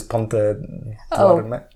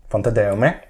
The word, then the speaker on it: Pontedeume: because it's a Gallego name, which is Pontedeume. – Ponte de Ome, there you go. I'm always thinking of saying puente in Spanish instead of ponte in Gallego.